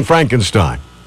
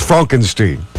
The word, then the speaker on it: Frankenstein: According to Entertainment Weekly, his most essential film is The Producers, followed by Blazing Saddles and Young Frankenstein. Oh, wait, I'm sorry, that's pronounced Frankenstein.